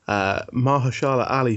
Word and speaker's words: Mahershala Ali: Do we do Best Supporting Actor, which was Mahershala Ali for Moonlight. – Yeah. – Pronounced that right, didn't I? – You did.